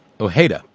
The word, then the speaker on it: Ojeda: And his name is Richard Ojeda, O-J-E-D-A, and that's pretty close to a half-passable pronunciation, as it would properly be done in Spanish.